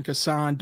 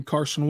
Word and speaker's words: Cason: The pronunciation guide officially says Kaysen, by the way. So I'm going to try my best to stay with Kaysen. I more comfortably say Kassan, but I'm going to try my best to say Kaysen from now on, just because that's what the official Thunder PR pronunciation guide says.